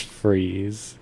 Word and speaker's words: Fries: Well, because Mr. Freeze's last name is spelled Fries. – Oh, really? – That's why it's Freeze, because his last name is actually Freeze, but it's spelled like Fries.